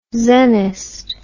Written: zennist

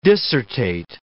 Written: dissertate

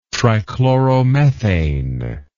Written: trichloromethane

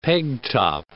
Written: peg top

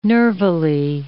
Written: nervily

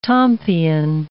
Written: tompion

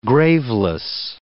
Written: graveless

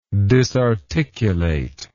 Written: disarticulator

